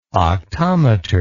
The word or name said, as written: octometer